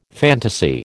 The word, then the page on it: fantasy: American English